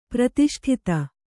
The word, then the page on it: ಪ್ರತಿಷ್ಠಿತ: pratiṣṭhita